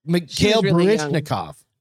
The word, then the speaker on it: Mikhail Baryshnikov: Mikhail Baryshnikov. Mikhail Baryshnikov. Mikhail. Yeah, you said it right.